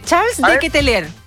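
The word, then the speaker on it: Charles De Ketelaere: Dije Charles de Ketelaer.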